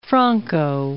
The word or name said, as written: Franco